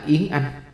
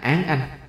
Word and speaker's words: Án Anh: Chú thích: Yến Bình Trọng tức là Yến Anh, còn gọi là Yến Tử, có khi đọc là Án Anh.